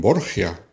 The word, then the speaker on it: Borgia: Los Borja, que en Italia se convirtieron en Borgia por cuestiones de pronunciación. En Italia la J no se sabe pronunciar, entonces de Borja pasó a Borgia y luego castellanizándolo pasó a Borgia, pero son la misma familia.